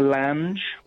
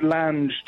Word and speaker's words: Lange: JL, I was thinking Jessica Lange. – Ooh. Is that how you say it, Lange? I suppose it is. I was worried about Lang, that. Lange just sounds better.